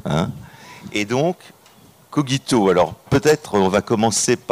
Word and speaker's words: cogito: Alors nous avons eu, pour les latinistes, une discussion pour savoir est-ce qu'on dit cogito ou cogito. Il semblerait qu'aujourd'hui, les deux prononciations soient acceptées, mais pour le principe, on va prendre cogito.